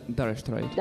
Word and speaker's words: Dire Straits: Al ser un nombre inglés, se pronuncia Dire Straits. – Dire Straits, molt bé.